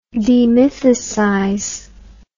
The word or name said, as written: demythicize